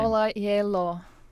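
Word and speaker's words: Ola Gjeilo: Ola Jello.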